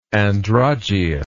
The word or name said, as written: Androgeus